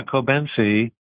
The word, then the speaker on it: Cobenfy: And correct me if I'm mispronouncing this, Cobenfy? – Yeah, Cobenfy.